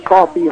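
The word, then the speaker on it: Koffi: Okay, my name is Coffee Hallman. It's K-O-F-F-I, but it's pronounced just like the stuff you get at Starbucks.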